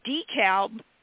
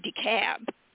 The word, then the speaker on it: DeKalb: And, in fact, what was so funny is I actually lived in Atlanta for a few years, and DeKalb would be pronounced in Atlanta, DeKalb. I couldn't get over it. It's like, it's DeKalb Avenue.